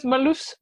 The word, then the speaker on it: Marloes: No, because because Marloes Marloes is such a difficult name for uh, English speaking countries as well.